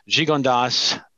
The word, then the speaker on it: Gigondas: And this wine here is Gigondas Pas de Leg, is how it's pronounced.